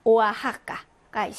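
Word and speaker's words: Oaxaca: 그래서 크스 발음이 있고, 자, 그 다음에 일부 지명에서는 강한 히읗 발음이 난다라고 했습니다. 그래서 멕시코가 있을 거고요. 또 오아하카가 있습니다.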